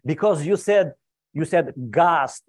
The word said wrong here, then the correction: ghost: It's not ghast. It's ghost. And it's not drawing, you know, it's drowning.